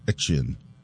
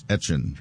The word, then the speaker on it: Etienne: Yes, and so he said, because he's back in Louisiana and now playing for the Saints, that he would go by the pronunciation that they would say in Louisiana, which always was, like, Etchin. – So, Etchin. It's Travis Etchin.